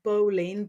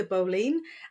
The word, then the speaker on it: boline: And so the white-handled knife, also called the bowline, B-O-L-I-N-E, the bowline.